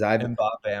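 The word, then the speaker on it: Mbappé: Is that how you say it, is Mbappe? Because Mbappe, I'm pretty sure.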